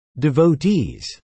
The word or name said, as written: devotees